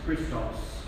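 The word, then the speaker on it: Christos (Χριστός): On the next page, X-P-I-C-T-O-C, it will be now pronounced Christos.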